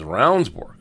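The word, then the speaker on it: Ravnsborg: What kind of a name is Roundsburg, you know? All I can say is it's Roundsburg. It's spelled R-A-V-N-S-Borg, B-O-R-G.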